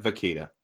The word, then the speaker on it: Vakita: It's called the Ween Knives Vakia. Am I saying it right? – I think it's Vakita. – Vakita. – Yeah, I think there's a T like in there. – Like Chiquita, but Vakita.